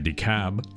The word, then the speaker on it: DeKalb: I think they pronounce it DeKalb, but there's an L in there. More